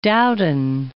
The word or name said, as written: Dowden